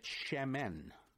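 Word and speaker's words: Shemen (שמן): Now, if we add one letter to the end of that, the letter N for November, we get Shemen. And it's a three-letter word. Spelled S, if I were to transliterate it, I'd spell it S-H-E-M-E-N.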